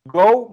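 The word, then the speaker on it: go (五): Primero, el nombre del protagonista, Go Milfune, que era Meteoro. El Go se pronuncia, es, el, número 5 en japonés es go, y también go es de ir, de vamos en inglés.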